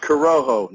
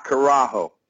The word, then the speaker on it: Corojo: Pronounced Corojo, not Carajo. If you say Carajo in Spanish, it means hell.